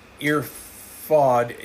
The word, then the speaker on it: Erfoud: And the Erfoud is spelled E-R-F-O-U-D.